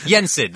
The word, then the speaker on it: Yensid: Oh, so she is how you say Disney backward? Yesnid. – Yeah, Sid. – Whatever. – Sid. Yensid. Yensid. There it is.